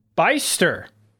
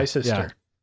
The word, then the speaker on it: Bicester: and from the UK, and since it's from the UK, I'm going to pronounce this Beister, F1. It's spelled Bicester. – Okay, yeah. – Bicester.